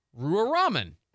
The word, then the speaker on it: Ruwa Romman: R-U-W-A-R-O-M-M-A-N. Rua Rahman.